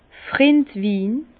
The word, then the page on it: Frindwin: Bas-Rhin; Herrlisheim